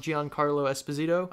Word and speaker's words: Giancarlo Esposito: Giancarlo Esposito.